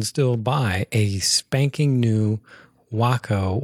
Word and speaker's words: Waco: And, you know, is it pronounced Waco? Is it Waco? Well, it's Waco.